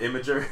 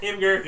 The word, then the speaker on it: Imgur: Q-U-S is disgust. All right, how do you say I-M-G-U-R? – Imgur. – Yeah. – All right. Even though they call it imager. – Imgur. – Imager. – It's Imgur. Imgur.